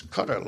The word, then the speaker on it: Qatar: Now there's Saudi Arabia for some years now, and Qatar, that's the pronunciation of it, by the way. People say, how do you pronounce that thing? Qatar? Qatar. No, it's Qatar, like the insect repellent there.